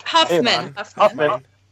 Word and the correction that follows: Hoffman: The answer would be a Dustin Hoffman.